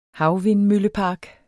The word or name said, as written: havvindmøllepark